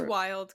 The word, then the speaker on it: Wilde: Okay, how do I actually pronounce her name? I can't even remember. Is it Wilde or Wilde? – It's Wilde because it's an Oscar Wilde reference, I think.